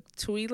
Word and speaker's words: Twi: the Tui language, I believe it's pronounced. Okay. T-W-I or T-U-I. It's actually spelled both ways.